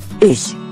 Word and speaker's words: Ich: Ich is the German word for I. Please notice that it doesn't sound like ich, I saw a mouse. It has a softer ending, like letting the air out of a tire.